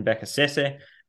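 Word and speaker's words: Beccacece: They've just appointed their sixth manager, if you include the caretakers in charge, Sebastian Becasese, which I'm assured that is how it's pronounced.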